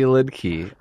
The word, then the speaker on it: Liedtke: Follow me on Twitter at Randy Liedtke. It's L-I-E-D-T-K-E.